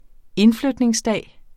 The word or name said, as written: indflytningsdag